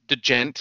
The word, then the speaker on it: djent: It's D-J-E-N-T, but it's I guess you don't pronounce the D, but you djent. So it's just djent.